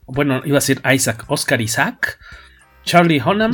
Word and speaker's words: Oscar Isaac: Oscar Isaac El Oscar también lo tienes que pronunciar distinto. Sí, pero el, artístico sí está tocado en inglés, es Oscar Isaac.